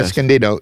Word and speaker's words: Escondido: We have Charles from Escondido, how you say it, California. – Escondido.